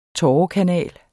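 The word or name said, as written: TÅREKANAL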